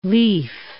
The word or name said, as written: lief